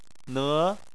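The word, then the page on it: ne: tone 2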